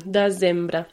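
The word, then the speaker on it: desembre: Mm, dezembre. Yeah, it's more dezembre. Of a kind of Z sound, yeah, like dezembre.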